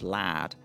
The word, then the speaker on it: lard: So one of the other ingredients for bread she talks about is lard, which she pronounces as lad, and that's still very much the pronunciation in large parts of Yorkshire and the north of England for words like car park.